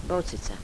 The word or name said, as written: bocica